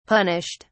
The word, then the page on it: punished: British English